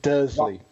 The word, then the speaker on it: Dursley: Thomas from Dursley, how do you say that? Is that right? – Dursley.